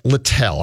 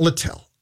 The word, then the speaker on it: Littell: Zach Littell. I'm saying that right, right? – Yep, you got it. – Zach Littell.